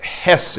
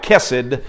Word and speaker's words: hesed: That's how you would pronounce it, hesed. It looks like kesed, but we would say hesed in how we would pronounce it in English.